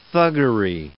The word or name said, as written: thuggery